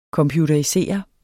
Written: computerisere

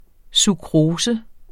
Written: sukrose

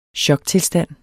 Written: choktilstand

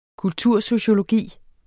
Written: kultursociologi

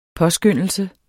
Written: påskyndelse

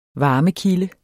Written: varmekilde

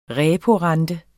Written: reporente

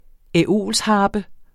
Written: æolsharpe

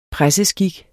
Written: presseskik

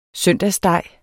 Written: søndagssteg

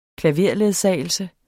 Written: klaverledsagelse

